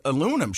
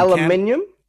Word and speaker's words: aluminium: But what happened was is there was an aluminum shortage and a lot of the aluminum. – Aluminium? – Aluminum.